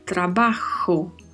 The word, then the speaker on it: trabajo: A particularidade de pronúncia está na letra J. Letra J então pronunciamos com esse som semelhante a um R, mas vindo mais da garganta. Trabajo, trabajo.